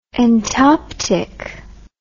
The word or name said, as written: entoptic